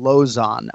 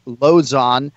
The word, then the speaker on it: Lauzon: We call him Lozon in the UFC. It's one of the easier names to pronounce, incidentally. Just say opposite of high, it's Lozon.